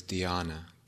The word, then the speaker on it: dhyana: Jhana is a word, is a Pali word, and it's spelled J-H-A-N-A. In Sanskrit, the word is dhyana, D-H-Y-A-N-A.